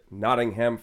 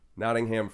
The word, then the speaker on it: Nottingham: Yeah, there's three syllables. I don't know if you noticed. – Nottingham.